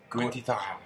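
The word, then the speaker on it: Gwynt y Ddraig: I'm going to pronounce that Welsh name. – Gwynty Dahl. – Very good.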